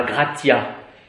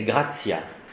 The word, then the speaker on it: gratia: Dans la prononciation universitaire restituée, pour qu'on ne puisse pas prononcer comme à la messe, ça fera gratia. Tandis que la prononciation italienne, c'est grazia, c'est doux.